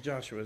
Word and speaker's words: Joshua: From the days of Joshua, this is Joshua, it's spelled Jeshua, but it's Joshua, the son of Nun, to that day, the people of Israel had not done so and there was very great rejoicing.